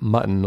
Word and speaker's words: Mutton: Thomas uh, Thomas Mutant um, Am I pronouncing that right? – I believe, uh, I believe it'd be like mutton, like mutton oh, chops.